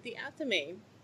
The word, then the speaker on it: athame: It's spelled A-T-H-A-M-E, and it is pronounced athame. Now, this is an old word, so that's why it sounds different than it looks.